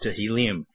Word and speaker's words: Tehillim: And so the Psalms are called in Hebrew the Book of Tehillim. If you're wanting to spell it, you would spell it T-E-H-I-L-L-I-M, and H-L-L is the root there, the word for praise, Hallel.